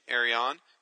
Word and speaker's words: Arion: Now, by the way, that is the official pronunciation, Arion.